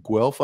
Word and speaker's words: Guelph: So born and raised in Guelph, Ontario, is that right? – That is correct.